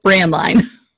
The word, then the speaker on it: Braendlein: It's honestly, there are so many superfluous letters in my last name. I feel like if you could pronounce it right the first time, it would probably mean that you couldn't read. It's pronounced Braendlein.